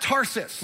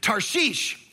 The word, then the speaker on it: Tarshish: It's not Tarsus. Like that's how we, we grew up learning. It's not even Tars ish. It's Tarshish is how you say this.